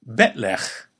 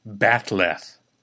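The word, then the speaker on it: bat'leth: Betlech is the Klingon word for a two-handed curved sword, typically mispronounced by non-Klingon speakers as a batleth.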